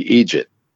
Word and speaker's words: idiot: idiot is pronounced basically idjit.